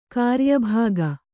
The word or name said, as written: ಕ್ರಿಯಾಭಾಗ